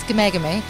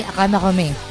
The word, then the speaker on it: Ghamaghami: It's Gamagami. That's the English pronunciation, but if you look at the Persian pronunciation, it's Amiri Ghamaghami. – Oh my, OMG. – An easier way to remember it is if you think of the Muppets... And remember the Manamana song, then you are three quarters of the way to remembering how to pronounce it.